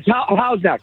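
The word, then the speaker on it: Houghs Neck: am I saying it right? Hoes Neck? – Yeah, Hoes Neck.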